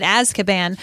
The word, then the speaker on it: Azkaban: And yes, that's how you say it, Azkaban.